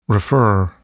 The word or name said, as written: refer